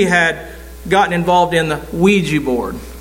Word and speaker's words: Ouija: Have you ever heard of a squeegee board? And you say, "Oh, it's a harmless game." No, it's not. It's not. Squeegee board. Ouija board, excuse me. Ouija board. Well, it is spelled a Q, so.